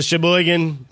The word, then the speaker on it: Sheboygan: So it's the Sheboygan, am I saying that right? – Yep.